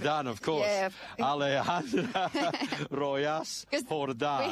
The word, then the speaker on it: Rojas: So do I pronounce it as Yas or Hus? Or Hus. Yes. Yes. Hus. Like a H. – Like in Alejandra Rojas.